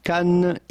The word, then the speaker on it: Cannes: Well, first you have to know that the S at the end of Cannes is definitely silent.